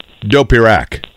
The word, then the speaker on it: Dopirak: But here's the thing: is it Dopirak or Dopirak? – It's Dopirak. There's a little bit of a emphasis in the middle there.